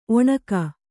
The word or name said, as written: ಒಣಿಗೆ